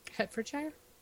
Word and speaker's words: Hertfordshire: Hertfordshire. – Hertfordshire? – Yeah, that sounds right, in okay.